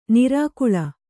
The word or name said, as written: ನಿರ್ಗಾಳಿ